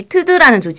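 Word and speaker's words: ㅌㄷ: 그래서 그 뿌리로 되는 조직이 트드라는 조직이에요. – 트드. – 네. 이름이 특이하네. – 네, 티읕 디귿 이렇게 씁니다. 근데 음. 트드라고 읽어요.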